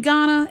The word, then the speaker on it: Ghana: You know, your husband actually died in Ghana. Am I saying that right? – Yes, that's correct.